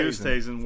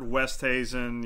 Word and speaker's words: Oosthuizen: All right, before I go, can you tell me, so Louis Oosthuizen, how do you say that one? – Louis Oosthuizen works. I've heard Westhuizen, yeah. Okay. Oosthuizen works, yeah.